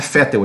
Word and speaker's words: Vettel: A pronúncia correta de V em alemão é F, ou seja, Vettel em alemão é Fettel, exatamente, é Fettel, Fettel.